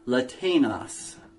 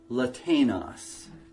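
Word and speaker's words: Latanos (Λατεινος): I submit that that name is the Greek name Latanos. Latanos.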